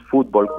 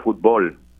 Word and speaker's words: fútbol: Es interesante que hay doble pronunciación posible, porque aquí en Puerto Rico no decimos fútbol con acento en la U, sino que decimos fútbol eh, con, fuerza de pronunciación en la O.